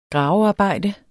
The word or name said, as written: gravearbejde